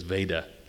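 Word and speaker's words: Vajda: Father Veda, it's V-A-J-D-A, but I understand the authoritative pronunciation is Veda, like Darth.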